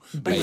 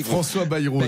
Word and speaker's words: Bayrou: Répétez après moi, Bernard, on dit François Bayrou. Bayrou.